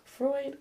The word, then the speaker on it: Freud: Freud. That's how you pronounce that.